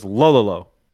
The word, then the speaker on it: Lololo: I imagine it's pronounced like DDD, so it's Lololo.